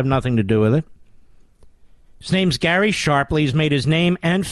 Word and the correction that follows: Shapley: Shapley, rather, is his name. Which is it, Mr. Bidus? We have both. I think it's Shapley. Shapley.